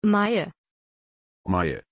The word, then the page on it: Maie: German